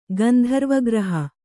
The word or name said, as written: ಗಂಧರ್ವಗ್ರಹ